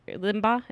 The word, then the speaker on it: Limbaugh: It was made popular by Rush Limbaugh, who is an extremely... Limbaugh? Is that how you pronounce it? Mm-hmm.